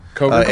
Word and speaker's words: Cover: Cover. It's spelled like cover, but it's pronounced cover because it's English and therefore pretentious.